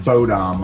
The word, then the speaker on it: Bodom: I keep saying Bodom, like it's Bodie, like it's like a dorky kid. – Bodom and Bodom are both kind of accepted.